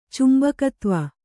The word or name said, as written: ಚುಂಬಕತ್ವ